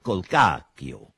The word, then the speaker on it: Colcacchio: double CH is pronounced CK in Italian, Colcacchio.